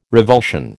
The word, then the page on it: revulsion: American English